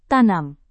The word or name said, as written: tanam